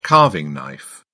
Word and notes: carving knife: British English

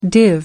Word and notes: div: American English

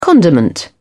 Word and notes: condiment: British English

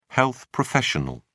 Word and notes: health professional: British English

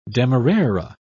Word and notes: demerara: British English

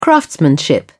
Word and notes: craftsmanship: British English

